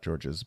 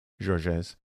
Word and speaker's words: Georges: So, Georges Kern, if I'm saying his name right, not Georges, but French, Georges Kern,